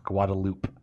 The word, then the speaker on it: Guadeloupe: Correction, it is pronounced Guadeloupe, French colony.